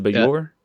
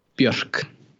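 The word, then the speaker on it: Björk: I think of, you know, I cannot pronounce her name, uh, B.J.O.R.K. What's what the famous artist yeah. that was also with in Sugarcubes? What how you pronounce her name? Is it Bjork? Bjork. – Bjork. Okay. yeah.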